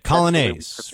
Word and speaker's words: Coglianese: Well, the family says it Coglianese. I know we say it incorrectly, but we've all sort of just said Coglianese throughout the years. So that's the one we go by.